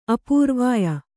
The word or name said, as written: ಅಪೂರ್ವಾಯ